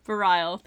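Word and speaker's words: Bareil: I felt like everything that Wynn and Bart, I still can't pronounce his name. Varile, thank you.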